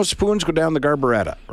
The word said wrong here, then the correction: garburator: right? And one of us was like, it's actually called a garburator. And she goes, well, whatever. I think garburetta is the British pronunciation.